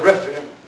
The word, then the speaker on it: Rephaim: The Philistines also came and spread themselves in the valley of Rephaim. Look at verse 22. And the Philistines came up yet again and spread themselves in the valley of Rephaim.